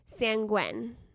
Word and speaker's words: sanguine: Sanguine. – Sanguine. – Sanguine. – We just did like the pronunciation and we already forgot. Wait, I think sanguine. – That the emphasis was on the first syllable.